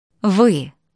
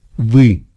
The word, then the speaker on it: Вы: And sure enough, the plural form of «you» rhymes with that. Listen. – «Вы». – «Вы».